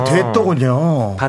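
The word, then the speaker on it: Duetto: 이탈리아 말로 듀엣토인데. – 아, 아, 듀엣토군요. 발음하기 편하게 듀엣토. 듀엣토.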